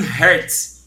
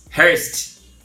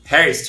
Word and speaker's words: Hearst: que é o William Hertz. Hearst. Hearst, eu acho que é a pronúncia, sei lá.